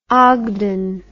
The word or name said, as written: ogdon